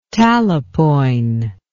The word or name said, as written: talapoin